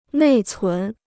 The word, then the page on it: 内存: nèi cún